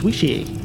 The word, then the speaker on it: wicce: I think it's pronounced Wishi, which is spelled W-I-C-C-E, which actually means wise woman.